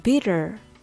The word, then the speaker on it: bitter: bitter は ter にアクセントがないので弱くなります。 bitter が bitter のようにアメリカ英語では ter が der のようにだらっとした発音になってしまうのです。